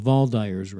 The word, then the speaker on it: Waldeyer's: Spelled with a W-A-L-D-E-Y-E-R apostrophe S. – Spell it again. – W-A-L-D-E-Y-E-R-S. – Hmm. Ring. – Yeah, and I guess in German you'd say the V instead of the W, Valdyer.